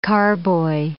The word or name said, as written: carboy